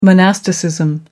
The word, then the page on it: monasticism: American English